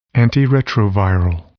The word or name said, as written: antiretroviral